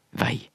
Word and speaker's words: veg: Uttalen er jo på bokmål vei, sjøl om vi skriver veg. Det er mange som trur eller påstår at hvis vi skriver veg, eg er på bokmål, så må me uttale det som veg. Nei, det treng me ikkje. Me kan skrive veg på bokmål og uttale det som vei.